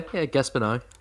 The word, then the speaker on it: Gaspar Noé: And this week's movie is none other than Climax by Gaspar Noé. Have I said that correctly? – Yeah, Gaspar Noé.